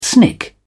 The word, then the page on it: snick: British English